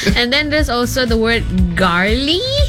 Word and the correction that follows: gnarly: Gnarly. Yeah, the G is silent.